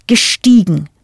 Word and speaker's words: gestiegen: The letters EI in steigen change to IE, pronounced E, giving you gestiegen.